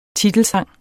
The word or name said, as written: titelsang